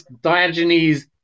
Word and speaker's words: Diogenes: So Diogenes. – Yeah, absolutely. Good pronunciation.